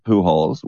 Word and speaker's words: Pujols: Albert Pujols, which is, it's spelled Pujols, P-U-J-O-L-S.